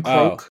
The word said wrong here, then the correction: Croce: Croce. Croce, maybe that sounds right? – Croce. Probably Croce, actually. If O-E, right? R-O-E. – C-R-O-C-E.